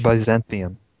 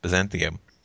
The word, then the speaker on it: Byzantium: Byzantium. – Yeah, Byzantium.